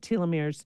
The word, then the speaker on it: telomeres: talk from time to time about telomeres. Did I say that right? Telomeres, right? – Yes, you did.